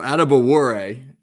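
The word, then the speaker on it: Adebawore: Are we even close with the pronunciation? – So Adebuwore, not bad bad at all.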